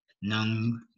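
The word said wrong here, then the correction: Ng: I think it's pronounced like Ing.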